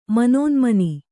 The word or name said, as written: ಮನೋನ್ಮನಿ